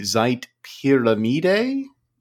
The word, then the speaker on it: Pyramide: But yes, the Zeit Pyramid, which I am pronouncing in a very Anglican way, because it's spelled, well, the word pyramid is spelled P-Y-R-A-M-I-D-E, so it could be Zeit Pyramide.